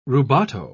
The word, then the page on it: rubato: British English